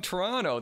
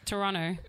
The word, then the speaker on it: Toronto: When I was a kid, I got yelled at for pronouncing the T or the second T in Toronto. – Toronto. – Yeah, so they say it like Toronto.